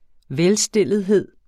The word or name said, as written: velstillethed